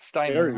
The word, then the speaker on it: Steinman: It's Steinman.